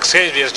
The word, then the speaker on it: Xavier: Riot at Javier's, at Xavier's, ya sé que se pronuncia así, denme chance, donde sale Quentin Quire, y es ese asunto de, oye, sí, nos dices, Kid Omega, Quentin, uh-huh.